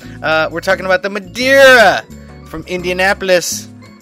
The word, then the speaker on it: Madeira: Love town of Madeira. Wait, Madeira? Madeira. Yeah, that's how they pronounce it, right? Madeira. I was always pronouncing it Madeira, but I think it really is Madeira.